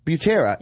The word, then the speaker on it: Butera: Butera.